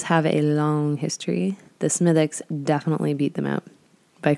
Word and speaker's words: Smithwick's: And that is Smithwick's, which is spelt S-M-I-T-H-W-I-C-K apostrophe. Yeah, no apostrophe S. Smithwick's. Yes, Smithwick's.